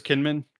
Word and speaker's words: Kinnaman: Chris Kinnaman. Is that how you say his name? – Yeah.